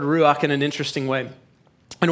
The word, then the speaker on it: Ruach: But it is the beginning of our new series called Ruach, not Ruark. You know, that's the southern way to say it, but it's not the correct pronunciation of it, Pedro.